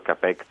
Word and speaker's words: Čapek: Sì, si chiama Rur, poi mi aiuti tu a dire bene la pronuncia in ceco, è di Karel Čapek, vero? – Sì, diciamo così, va benissimo Čapek, Čapek.